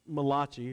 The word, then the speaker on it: Malachi: Well, by around 430 BC, they have returned back home to Judah, and Malachi, or Malachi, if you prefer the Italian pronunciation, begins to prophesy.